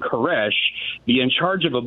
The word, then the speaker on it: Koresh: And the Hebrew pronunciation of Cyrus is Koresh.